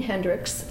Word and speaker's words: Hendricks: Gene Hendricks, H-E-N-D-R-I-C-K-S.